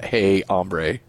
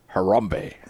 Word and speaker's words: Harambe: It's not hey, hombre. It's Harambe.